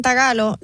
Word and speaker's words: tagalo: Espérate, hay un lenguaje que se llama trágalo. – Tagalo. – No, tagalo. – Oh. – No se dice filipino, se dice tagalo.